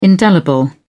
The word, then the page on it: indelible: British English